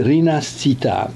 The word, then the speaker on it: Rinascita: Wprawdzie egzemplarze Unity i Rinascita, pisze się jak się wymawia, Rinascita, docierały do Polski bez przeszkód, przecinek, ale ilość ludzi czytających po włosku była bardzo ograniczona.